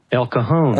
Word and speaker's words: El Cajon: Are you sure that's how it's pronounced? – I think it is. – El Cajon. – What is it? – El Cajon. – Oh, right. I think that's right.